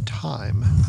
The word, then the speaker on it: Tyme: T-Y-M-E is the way you spell that, time.